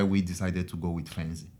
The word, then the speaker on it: Fainzy: So for your Fainzy, am I pronouncing it correctly? – Yeah, Fainzy Technology.